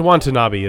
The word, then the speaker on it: Watanabe: Um, and then Ken Wanta being rude to other ants. Wanta not being rude. Sorry, I messed that up.